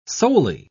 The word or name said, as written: solely